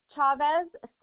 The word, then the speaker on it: Chavez: A-R-I-N-A. – Okay, C-Z-A-R-I-N-A, last name? – Chavez, C-H-A-V-E-Z. – C-H-A-V-E-Z? – Yes.